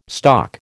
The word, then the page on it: stock: American English